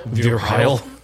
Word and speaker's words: Virel: I think that's what we've settled on how to pronounce Virel. V-I-R-E-L.